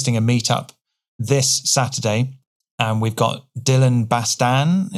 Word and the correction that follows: Bastian: Is that how you pronounce it? – Dylan Bastian.